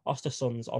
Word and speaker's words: Östersunds: And in August 2018, you joined Swedish side Östersunds on loan. I think I've definitely botched that pronunciation. – Oh, you actually got it right. Okay, I'll take that.